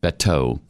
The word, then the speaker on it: Beto: You mispronounced his name. It's Beto. – Beto.